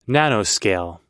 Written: nanoscale

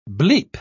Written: bleep

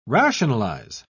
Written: rationalize

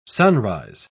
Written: sunrise

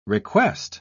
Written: request

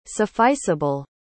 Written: sufficeable

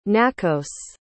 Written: nakos

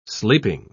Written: sleeping

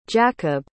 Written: jacobe

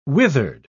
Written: withered

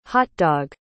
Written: hotdog

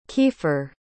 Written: kiefer

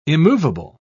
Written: immovable

